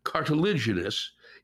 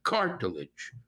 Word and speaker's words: cartilaginous: The third syllable in cartilaginous is stressed, unlike its root cartilage, where the first syllable is stressed, and which I'm sure you know how to say, cartilage, right? Don't let that fact throw you off, Lewis, when you say cartilaginous, still with that soft G.